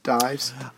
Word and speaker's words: Dies: Senator Martin Dees. No, Congressman Martin Dees. – Dies. – Dies. Junior. You told me it's pronounced Dies.